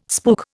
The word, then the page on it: spook: Indian